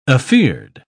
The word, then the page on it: afeared: British English